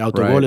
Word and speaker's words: autogol: Autogol is how you say it in Spanish, but an own goal in English.